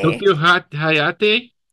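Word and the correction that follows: Tokihayate: Toki Hayate.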